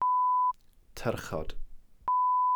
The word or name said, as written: tyrchod